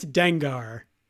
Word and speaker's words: Dengar: Rhymes with Dash Rendar. It's Dengar.